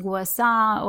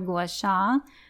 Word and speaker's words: Gua Sha: And you'll hear it pronounced Gua Sa or Gua Sha. I think both are correct. I've heard them used by native speakers interchangeably.